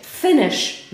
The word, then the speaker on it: Finnish: Zobaczcie, Finnish media, right? Przez dwa n i wymawia się tak samo jak finish, czyli skończyć.